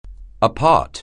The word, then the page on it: apart: anglais